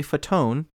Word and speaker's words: Fatone: And then Joey Marbalone or whatever. – Fatone. – Joey Torbalone. – Fatone. It's spelled like fat one. Joey fat one, but Fatone.